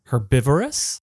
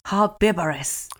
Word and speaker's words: Herbivorous: じゃあ、カーネバレスの反対の草食動物ってなんて言うんだろう、ね、草食は、yeah, depends on whether you pronounce the H or not. Herbivorous? – Herbivorous. – Herbivorous, yeah.